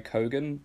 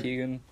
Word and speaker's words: Keoghan: I googled how to pronounce his last name. I think it's Barry Keoghan. Keoghan.